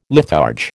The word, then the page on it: litharge: American English